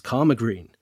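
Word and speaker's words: Caldmore Green: 7-year-old Nicholas Baldry was very specific that he pronounced Coldmore Green as Karma Green, meaning he was almost certainly from the vicinity.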